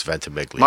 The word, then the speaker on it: Ventimiglia: It's Ventimiglia.